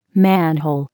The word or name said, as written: manhole